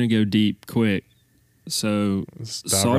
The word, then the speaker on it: Saudade: And I'm going to say it wrong, so correct me. It looks like Saudade. – Oh, that sounds better than the way I would pronounce it. – What, Saudade? Saudade? – I would say Saudade, but that might be the Deep South version. – Sounds like Deep South version. But what? Okay, it's Saudade.